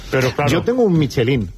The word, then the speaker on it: Michelin: Michelin. A ver, aquí en la península ibérica decimos Michelin.